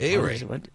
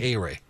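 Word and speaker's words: Ehire: His first name, E-H-I-R-E. I can't pronounce it. – A-Ray. Just think A-Ray.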